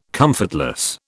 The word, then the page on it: comfortless: British English